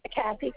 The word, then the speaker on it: Kathy: Kathy, K-A-T-H-Y. – Okay, and your last name? – R-O-D-E-N, Roden. – You said R-O-E-N-D? – Yeah, ma'am, R-O-D-E-N.